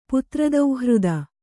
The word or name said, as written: ಪುತ್ರದೌಹೃದ